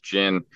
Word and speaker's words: GHIN: The mobile app that is offered through GIN, and it's spelled G-H-I-N, but it's pronounced GIN.